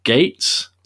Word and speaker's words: Gaetz: And it all kind of started around the time that Matt Gaetz, am I pronouncing that right, Dave? – Yeah. – Do you know? – Yeah, yeah, you are. – Matt Gaetz,